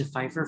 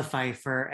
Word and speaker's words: Pfeiffer: Hello, everyone, and welcome to Pfeiffer Fridays, where we walk you through the films of one Michelle Pfeiffer, and every F word automatically has a silent P.